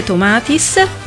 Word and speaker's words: Tomatis: Alessandro Pillinini ha scritto un libro, Il potere dell'ascolto, dalle teorie Tomatis, ho detto bene, sì.